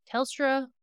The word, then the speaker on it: Telstra: According to the prosecutor, the state presented his the evidence as part of this case called the Telestra or the Telstra. Sorry, I keep saying it wrong. Telstra Living Witness Project.